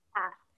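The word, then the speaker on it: fat (八): Oh, and then another number thing is a lucky number is 8 because in Cantonese, 8 is pronounced fat, and it sounds like fat, which is like wealth, and like it brings you wealth.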